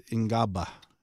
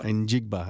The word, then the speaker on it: Njigba: 23, Jackson Smith Ngaba. – Njigba.